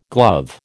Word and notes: glove: American English

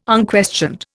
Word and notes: unquestioned: Indian

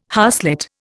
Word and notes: harslet: Indian

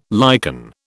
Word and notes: liken: British English

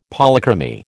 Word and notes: Polychromy: American English

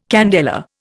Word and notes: candela: Indian

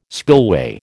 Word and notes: spillway: American English

